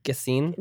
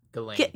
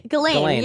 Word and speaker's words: Ghislaine: Isn't it Gassine? – No. It's like. – Yeah. I don't know how to Maxwell. – Yeah. – Ghislaine. – Ghislaine. Yeah, Ghislaine.